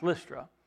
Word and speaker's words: Lystra: You want to think it's Lystra the way it's spelled, but it's pronounced Lystra. L-Y-S-T-R-A.